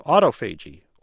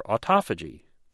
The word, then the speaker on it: autophagy: It's a process known by the technical term autophagy, or autophagy. It seems to be pronounced both ways.